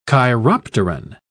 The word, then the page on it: chiropteran: British English